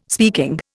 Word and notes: speaking: Indian

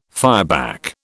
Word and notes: fireback: British English

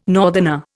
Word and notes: northerner: Indian

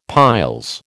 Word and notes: piles: American English

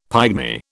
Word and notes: pygmy: British English

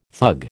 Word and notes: thug: American English

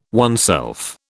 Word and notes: oneself: British English

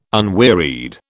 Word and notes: unwearied: British English

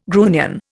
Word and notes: grunion: Indian

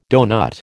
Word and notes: doughnut: American English

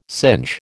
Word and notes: singe: American English

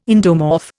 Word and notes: endomorph: Indian

